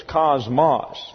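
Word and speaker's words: kosmos: That's K-O-S-M-O-S. These are omicrons here, not omegas, so it gets a short A sound in the English, so it's cosmos. But when the word comes over into the English, it's spelled like this. C-O-S-M-O-S.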